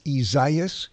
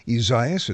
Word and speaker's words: Isaias: Hurricane, which is entitled, it's almost unpronounceable for some, but it's Isaias. Isaias, it's the Spanish way of saying Isaiah.